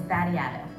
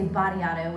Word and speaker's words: Batiato: Or they would say Batiato, which then, of course, in fifth grade sounded a lot like body odor.